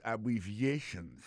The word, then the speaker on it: abbreviations: Abbreviations, accent tonique là, tombé sous troisième syllabe là.